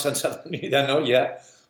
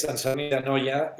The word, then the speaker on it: Sant Sadurní d'Anoia: Well, you pronounce Sant Sadurní d'Anoia much better than I pronounce the English language. But yes, Sant Sadurní d'Anoia is the capital of Cava, is the town that gathers most of the cellars.